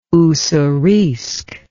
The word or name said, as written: Ussuriisk